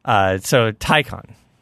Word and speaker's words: Taycan: Taycan rhymes with icon. That's how you can remember it.